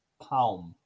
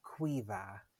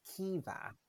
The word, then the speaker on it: Caoimhe: Second one is C-A-O-I-M-H-E. And I'm going to say Kaum. Kaum. – Not even close. We don't have the letter K in Irish, so our C's are pronounced qu, qu. A-O-I is pronounced E. And M-H's are pronounced V, like V's. So that's pronounced Quiva, Quiva.